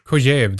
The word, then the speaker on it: Kojève: I assume it's Kojève. – Kojève, that's right.